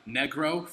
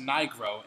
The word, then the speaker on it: Nigro: I pronounced my friend Nick, his last name as Negro for fucking like 4 years. And it was actually pronounced Nigro, and no one ever corrected me.